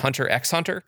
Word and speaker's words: Hunter x Hunter: Is it Hunter x Hunter? – Yes.